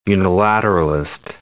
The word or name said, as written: unilateralist